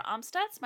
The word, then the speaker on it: Amstutz: You can find me on all social platforms at Sandra Amstutz. My last name is spelled A-M-S-T-U-T-Z.